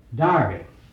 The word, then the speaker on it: Derby: George Fox leads you to believe that Justice Bennett at Derby, and I suppose you know that E before R in England is pronounced by, like A. Do you recognize that? If you have a clerk, what we call a clerk, then England's a clerk. And so Derby is Darby.